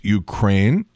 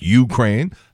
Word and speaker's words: Ukraine: Also, it's Ukraine, accent on the second syllable. It's not Ukraine.